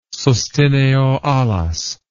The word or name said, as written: sustineo alas